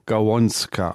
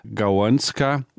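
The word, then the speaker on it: Gałązka: Das Ganze spricht sich etwa Gawonska aus. Das L mit Strich durch ist ein U. Und völlig richtig gehört zu Polnisch Gawonska, Ästchen, Zweig, kleiner Ast.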